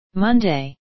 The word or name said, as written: monday